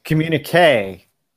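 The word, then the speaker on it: communiqué: C- communique.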